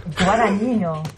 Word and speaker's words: Guadagnino: Guadagnino.